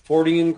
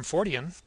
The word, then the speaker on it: Fortean: I'm also involved with a Fortean group. Are you familiar with lo-fi? – No, but I'm familiar with the term Fortean, or Fortean, yeah. as John Keel would pronounce it.